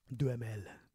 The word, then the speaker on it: Duhamel: Duhamel.